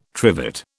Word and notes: trivet: British English

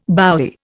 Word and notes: bowie: Indian

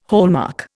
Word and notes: hallmark: Indian

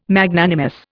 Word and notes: Magnanimous: Indian

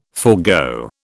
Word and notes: forgo: British English

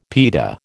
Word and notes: pita: American English

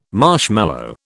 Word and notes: marshmallow: British English

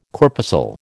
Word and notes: corpuscle: American English

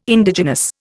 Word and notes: Indigenous: Indian

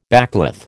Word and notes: backcloth: American English

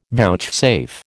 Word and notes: vouchsafe: American English

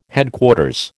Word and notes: Headquarters: American English

